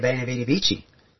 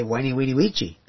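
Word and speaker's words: veni vidi vici: And so words, you know, like Caesar coming and saying, I came, I saw, I conquered, a lot of people say Bene Vidi Vici. But in another pronunciation key of Latin, you could say "weni weedy weechi." It doesn't sound quite so dramatic.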